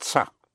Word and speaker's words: ça: Ce n'est pas, spelled C-E-N apostrophe E-S-T, P-A-S. Ce n'est pas. But otherwise, it by itself is ça. Spelled C-A, and it has a little hook on the C to give you the pronunciation of an S instead of ka, make it ça.